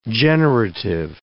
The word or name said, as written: generative grammar